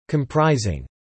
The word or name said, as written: comprising